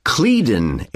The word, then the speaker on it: Cledon: Well, it's called Clédon Airport. – How do you spell that? C-L-E-D-O-N? – That's right.